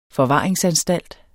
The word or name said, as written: Forvaring